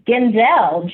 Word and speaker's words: Ginzel: And then also Chasing Leadership, Linda Geisel? – I think it's Ginzel. G-I-N-Z-E-L.